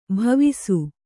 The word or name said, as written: ಬವಸು